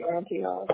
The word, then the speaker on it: Antioch: Reed as in R-I-E-D or? – No, it's R-E-E-D. – Okay, Reed Drive, got it. And then also Antioch, I can never pronounce that right. California. – Yeah, I'll say Antioch.